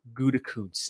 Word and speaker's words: Gutekunst: We're not moving on until you say it right. – Yeah. – Gutekunst. – There you go.